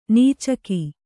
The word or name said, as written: ನುಚ್ಚಕ್ಕಿ